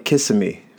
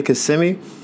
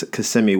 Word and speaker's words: Kissimmee: But as a kid, I just looked at the way it was spelled, and I used to call it Kissimmee because it's K-I-S-S-I, right? So I was, oh, Kissimmee, yeah, I went there. And someone's like, oh, you went to Kissimmee? And they didn't say it like, you're wrong, you're a jerk. It was just like, I had never heard it said properly.